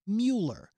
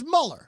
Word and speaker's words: Mueller: But if you hear a name over and over again, like Robert Mueller, who's doing the Trump investigation, he spells it M-U-E-L-L-E-R, which is spelled Mueller. But his name is Mueller.